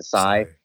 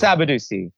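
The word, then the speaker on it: Sadibou Sy: I've been mispronouncing Sabadu's last name. I've been calling him Sai. It's Sabadu C.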